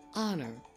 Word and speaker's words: honor: Then we have a word like hour and honor. The words start with H, but you don't pronounce the H.